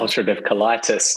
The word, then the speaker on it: ulcerative colitis: You can say it for ulcerative colitis.